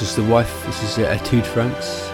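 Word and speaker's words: Étude: And it is Etude, not Etude. I always thought you pronounced it Etude.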